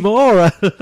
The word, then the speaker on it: Maura: That is Moira Higgins. – Oh, we love Moira. Yeah. – It's Maura. – Maura. – Okay, Maura. Sorry.